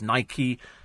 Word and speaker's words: Nike: Nike rhymes with bike. No, it doesn't. Rhymes with spiky. So it's Nike spiky.